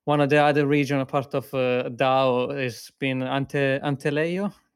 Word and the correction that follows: Alentejo: Sorry, my pronunciation. – Oh, Alentejo, sorry.